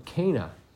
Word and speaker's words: Cana: Cana.